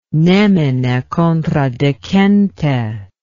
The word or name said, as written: nemine contradicente